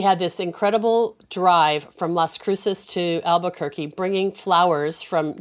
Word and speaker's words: Las Cruces: And it's La Cruces? Is that how you say it? – Las Cruces, yes.